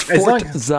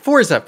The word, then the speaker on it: Forza: It's pronounced Forza. – I don't give a crap. Forza.